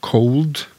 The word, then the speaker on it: cold: Og cold, altså at det er kaldt, C-O-L-D, skrives ikke som noe diftong, men det uttales som en diftong.